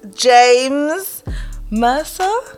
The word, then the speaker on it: James Mercer: And I'm here with an amazing guest called James Mercer.